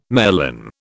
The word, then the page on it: merlin: British English